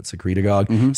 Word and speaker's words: secretagogue: Okay, so it's sirmorelin, if depending on how you want to pronounce it, which is a IGF-1 and growth hormone secretagogue, sounds like synagogue, but secretagogue, stimulates growth hormone release.